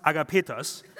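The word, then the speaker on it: agapetos (ἀγαπητός): The word beloved that John used here, in Greek is written agapetos and is pronounced agapetos, which means, there's just another H in there because it's like a pertos, so agapetos.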